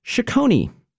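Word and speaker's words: Chacony: The Italians would call that a chaconne, but in Baroque England, they pronounced it chaconi.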